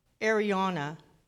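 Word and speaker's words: Araunah: floor of Arun, I can't never say his name. It's called Arauna, the Jebusite.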